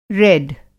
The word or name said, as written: रेड